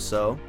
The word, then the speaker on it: Seo: So my name is James So. People think it's CO, but the E is silent. – Gotcha. – The James So.